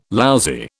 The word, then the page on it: lousy: British English